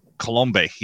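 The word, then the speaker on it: Coulombe: And Danny Colombe, yeah, that's how you pronounce his last name.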